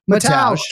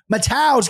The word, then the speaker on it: Mateusz: Mateusz Gamrot, am I saying that right? Did I butcher that one too? – Mateusz. – God! Mateusz Gamrot.